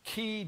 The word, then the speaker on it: Keay: How do you pronounce this guy's name? K-E-A-Y. – K-E-A-Y. – Key Davidson. And he started this column.